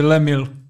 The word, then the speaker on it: Les Mills: Já bych řekl, že to je francouzsky. Ač přečetl bych to jako bez, bez esek na konci, takže lemil.